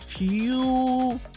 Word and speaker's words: furious: So I think that's the thing that we're missing for some of us here is we tend to make the oo in the pronunciation to sound like eh. And it becomes, it starts sounding like furious. Yeah. Fear, like fear, furious. So it's not fear.